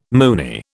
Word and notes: Moonie: British English

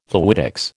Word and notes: fluidics: American English

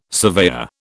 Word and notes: surveyor: British English

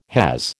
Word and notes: has: American English

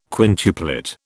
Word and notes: quintuplet: British English

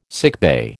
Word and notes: sickbay: American English

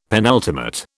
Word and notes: penultimate: British English

